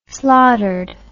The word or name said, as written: slaughtered